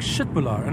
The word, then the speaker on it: Köttbullar: The way it's written, it's Köttbullar. And when I was in Sweden with friends of mine, somebody was like, "Shitbullar," and I'm like, "What?"